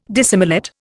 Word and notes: dissimilate: Indian

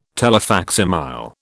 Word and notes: telefacsimile: British English

